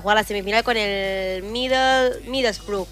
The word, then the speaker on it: Middlesbrough: Middlesbrough.